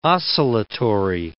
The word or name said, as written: oscillatory